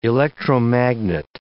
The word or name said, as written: electromagnet